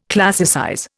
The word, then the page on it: classicize: Indian